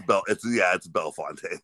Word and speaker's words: Bellefontaine: I want to say, Bell Fountain. I don't think I say it right. Some people say Bellefontaine or it's Bellefontaine.